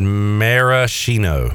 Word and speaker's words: maraschino: Jamie said maraschino. Maraschino. That sounds right.